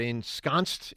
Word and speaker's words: ensconced: ensconced in the sixth seed? – Yeah. – Did I say that right? Ensconced? – That's correct.